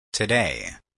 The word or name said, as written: today